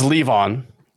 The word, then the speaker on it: Levon: Hi, LaVon, Robbie, Richard, and Rick. – It's LeVon. That's how you pronounce this.